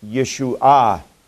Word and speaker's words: Yeshua: And when it's used as a noun, the word is Yeshua, with the emphasis on the last syllable.